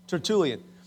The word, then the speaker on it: Tertullian: According to one of the early church fathers, it's a guy named Tertullian. I'm probably pronouncing it wrong, Wyatt. Did I get it right? Okay, good. Tertullian.